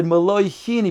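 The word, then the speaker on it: Meloi Hin (הין): And therefore, since Shmai Avtali on his Rabbeim were Geirei Tzedek, they couldn't pronounce the word Hin, and they said In. So therefore, he said, Meloi Hin, as not to make a mistake and say In, meaning you don't need any amount, will then poisil the Mayim She'uvim at the mikvah. So he emphasized Meloi Hin. And therefore, he said Meloi Hin, he pronounced the hay, and he said Meloi Hin together, as to then make it clear what the Rebbeim Shmai Avtalion meant when they said Hin.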